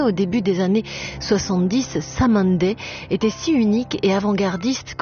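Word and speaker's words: Cymande: Donc déjà, on vous prévient, anticipez, prononcez donc à l'anglaise le groupe Samandi,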